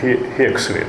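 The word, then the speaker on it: Huxley: в частности Томас Хаксли, ну на другом произношении то Хексли, да, Хексли.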